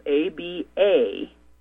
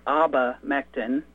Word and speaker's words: abamectin: The very first ingredient, the very first listing is spelled ABAMECTIN, abamectin.